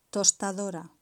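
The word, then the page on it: tostadora: Spanish (Spain)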